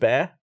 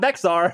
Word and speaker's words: Bexar: B-E-X-A-R. – Bizarre? – Is it bear? – It's bear. – Bear. – What? – Not Bexar. That's what I used to say. – Bex, the county. – The X is silent. – The silent X. Bear County. – B-E-X-A-R. Bear.